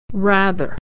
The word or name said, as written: rather